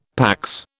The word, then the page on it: PAX: British English